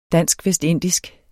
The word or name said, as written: VESTINDISK